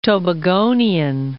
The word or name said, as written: Tobagonian